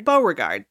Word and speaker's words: Beauregard: Beauregard.